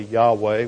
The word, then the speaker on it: Yahweh: That's the translation of the Hebrew, which is pronounced Jehovah, or more properly, Yahweh. We really don't know how to pronounce the Tetragrammaton, the name of God, but probably best we can do is Yahweh.